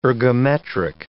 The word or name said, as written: ergometric